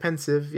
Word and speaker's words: Pensieve: Is that how you would pronounce that? I know it's like a pensive. Pensive. – Yeah, no, I think pensive, yeah. – That's how Jim Dale pronounced it, so yeah. I will as well.